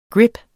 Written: grip

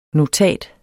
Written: notat

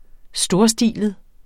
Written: storstilet

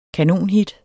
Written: kanonhit

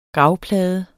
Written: gravplade

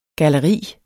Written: galleri

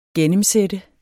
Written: gennemsætte

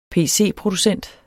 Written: pc

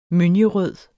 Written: mønjerød

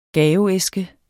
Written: gaveæske

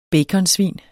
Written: baconsvin

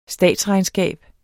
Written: statsregnskab

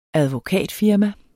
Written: advokatfirma

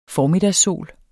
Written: formiddagssol

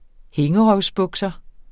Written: hængerøvsbukser

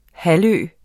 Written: halvø

